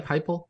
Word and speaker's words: Heupel: Tennessee is coming off an 11-2 season in 2022 under Josh Heupel. Did I say that right? Heupel? – Yep, you got it.